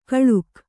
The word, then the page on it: ಕಳುಕ್: kaḷuk